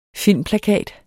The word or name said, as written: filmplakat